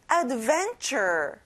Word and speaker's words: adventure: 모험, adventure. 여기 끝에 er 발음을 잘해주고요. van에 강세를 넣어보는 거예요. Adventure.